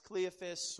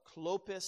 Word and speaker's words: Clopas: and it could be pronounced Cleophas or perhaps Clopas, C-L-O-P-A-S.